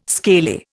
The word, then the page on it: scaly: Indian